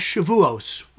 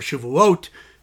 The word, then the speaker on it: Shavuot: It's the holiday known as Shavuos, or Shavuot, in the Sephardic pronunciation.